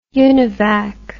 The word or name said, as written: univac